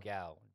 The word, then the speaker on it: Gal: Gal, the lady that played it's Gal, G A L.